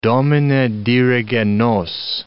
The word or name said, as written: Domine, dirige nos